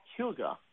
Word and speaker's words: hygge: It's a Danish word, and it's got a funny spelling. It's spelled H-Y-G-G-E. H-Y-G-G-E. It's pronounced hygge.